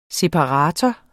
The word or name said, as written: SEPARATORER